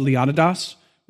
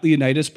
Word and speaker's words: Leonidas: By the way, when you study, at least when I studied this battle, he was called Leonidas, was how I was told to pronounce it. Not Leonidas, but whatever.